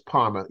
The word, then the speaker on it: Parma: I'm sure I didn't say it right, it's not Parma, it's Parma. Your sister bring a black guy home.